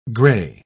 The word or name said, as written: grey